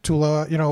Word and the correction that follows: Taulia: T- uh, Taulia Tagovailoa.